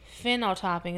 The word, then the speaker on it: phenotyping: Well, they do something called DNA phenotyping.